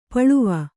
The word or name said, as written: ಪೊಳವೆ